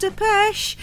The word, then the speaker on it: Depeche Mode: all the presenters were calling them Depeche Mode. No, there's no accent on the E, it's just Depeche.